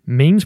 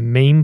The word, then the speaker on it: meme: memes propagate themselves in the meme pool by leaping from brain to brain. – This broad sense, we can call it imitation. He says that it can be pronounced mem, but let's go with meme.